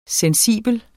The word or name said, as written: sensibel